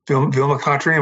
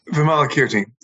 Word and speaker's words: Vimalakirti: I had to kind of smile inwardly when I heard your quote from Vimakakirti. Am I pronouncing it right? – Vimalakirti.